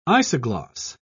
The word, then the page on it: isogloss: British English